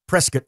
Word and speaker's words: Prescott: That's how you say it there. Prescott, not Prescott.